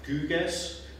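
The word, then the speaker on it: Gyges: So all accounts agree on the basic facts, which is that Guges, I'm going to use the German pronunciation because we're going to listen to the opera, some of the opera later.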